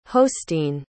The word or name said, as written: hosteen